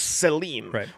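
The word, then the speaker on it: tselem: And so the Hebrew word here is Selim, which we would pronounce sort of like S-E-L-E-M, but it's actually written out T-S-E-H-L-E-M.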